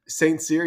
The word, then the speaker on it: St. Cyr: I guess the first question is, are we saying it correctly? – St. Cyr, yeah, you're saying it Woo-hoo!